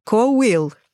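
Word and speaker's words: cá bhfuil: Вот, я бы тоже сказала, что это какой-то кабвил, а читается коуил.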